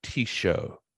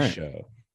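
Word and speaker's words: Tixou: Says that it's T-show. – All right. T-show. Good to know.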